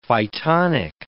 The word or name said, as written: phytonic